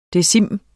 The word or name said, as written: decim